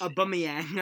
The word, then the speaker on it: Aubameyang: Aubameyang.